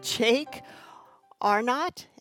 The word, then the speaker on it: Jake Arnott: I'm your host, and with us today, all the way from across the pond, is Jake Arnott.